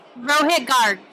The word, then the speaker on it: Rohit Garg: Rohit Garg. – That's awesome. – Did I get it right? – That's it. – Oh! – Yeah, it's Hindi is phonetic.